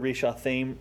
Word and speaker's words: Rishathaim: This word, Rishathaim, rhymes with the place name, Mesopotamia, so it was a play on words.